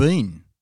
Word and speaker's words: Biehn: And Michael, and I, I can't pronounce his name probably, Michael yeah, Ben? Beyond, Who played uh, Kyle Reese? He was excellent. – uh, Michael that. Biehn. – Yeah, it's Michael Biehn.